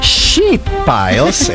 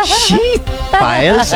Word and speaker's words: sheet piles: Ito, and this is where you have to be very careful how you pronounce it. Sheet piles. Ayan. Mahaba po yun, ha? Sheet piles.